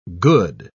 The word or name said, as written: good